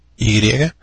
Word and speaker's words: Wontoy: Wontoy se escribe WONTOY.